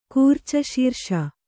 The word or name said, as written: ಕೂರ್ಚಶೀರ್ಷ